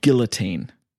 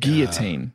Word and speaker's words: guillotine: So we were called Guillotine, not Guillotine.